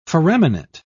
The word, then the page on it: foraminate: British English